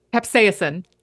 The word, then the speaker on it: capsaicin: We were talking about deterrence, and you mentioned the capsaicin. Is that how you pronounce it? – Capsaicin. – Capsaicin. Yeah.